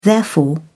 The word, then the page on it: therefore: British English